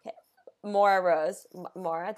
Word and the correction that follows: Moira: did I say that right? – Moira.